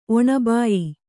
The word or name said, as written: ಒಣಬಾಯಿ